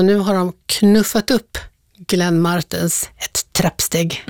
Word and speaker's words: Martens: Glenn Martens med betoning på R-et. Vem är det? – Ja, det är inte Glenn Martin, utan Glenn Martens.